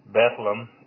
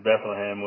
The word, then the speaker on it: Bethlehem: So we have, and they pronounce it Bethlehem, not Bethlehem,